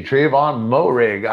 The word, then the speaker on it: Trayvon Moehrig: I just wonder what it felt for Trayvon Moehrig. Did I pronounce that correctly? – Yeah, I think he did actually. Yep. – Oh yeah. All right. Good for me. Trayvon Moehrig.